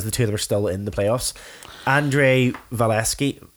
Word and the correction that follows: Vasilevskiy: is that how you say his name? Vasilevskiy.